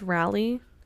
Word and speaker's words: Raleigh: I covered a story one time, and this was a place, and I was saying it wrong. Is it Raleigh? It's Raleigh. Okay, it's...